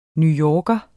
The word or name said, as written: newyorker